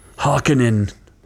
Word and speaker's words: Harkonnen: there's readings from Frank Herbert that probably say Harkonnen, but I don't know. I just know that's what it's been since the 1985 movie. That's how people pronounced it.